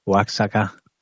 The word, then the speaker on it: Oaxaca: But I've heard that a third of the buildings in Yucatan, Oaxaca, how do you pronounce that state? Oaxaca.